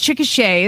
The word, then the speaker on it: Chickasha: It looks like Chick- Chickasha, but it's Chickasha is how you pronounce it here.